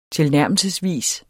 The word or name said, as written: tilnærmelsesvis